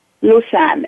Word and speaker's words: Lusan: Lusan, L-U-S-A-N, Lusan. – I did pronounce it correctly, Pearly Lusan.